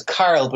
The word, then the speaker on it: Karol: My name is Carl, but it's spelled K-A-R-O-L.